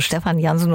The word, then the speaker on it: Stefan Jansen: Und mit diesem Sound sind Sänger und Keyboarder Stefan Jansen, ich würde schon Englisch aussprechen, also Stefan Jansen und Schlagzeuger Tom Walter aus Erfurt, ja sehr erfolgreich.